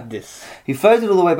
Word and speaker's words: Charybdis: Charybdis. – Charybdis.